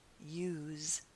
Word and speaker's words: use: As a verb, the pronunciation is use with a Z sound, a vibrating Z, use. But as a noun, the pronunciation changes and is use with an S.